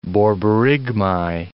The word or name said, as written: borborygmi